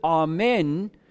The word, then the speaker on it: Amen: Now we have to remember the word Amen in the Hebrew is actually pronounced Amen, and it means truth.